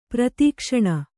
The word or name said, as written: ಪ್ರಾದಕ್ಷಿಣ